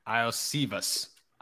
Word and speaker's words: Iosivas: I, it's like, and Andre is Islavas. Uh, it's I, I I butchered that so bad, but like oh he, yeah, it's uh, Andre I Iosivas. – Iosivas, yeah.